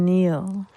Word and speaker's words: kneel: How are you spelling Neil? – K-N-E-E-L, Neil.